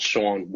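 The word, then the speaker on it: Shaun: I'm on Twitter at Sean Woodley. It's spelled S-H-A-U-N-W-O-O-D-L-Y.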